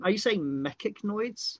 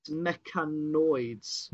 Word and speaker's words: mechanoids: Are you saying mechanoids? – What should I be saying? – It's mechanoids. – Mechanoids.